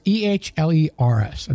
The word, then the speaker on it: Ehlers: Is it Ewers or Ehlers? It's Ehlers, and I've always pronounced it Ehlers.